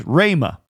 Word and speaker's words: rhema: In Greek, that is the word rhema, or in modern English, rhema, as we would pronounce it.